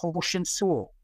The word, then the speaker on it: Hoshin Sul: There are 90 techniques in the art of Hoshin Sul, which is the Korean pronunciation of Goshin Jutsu.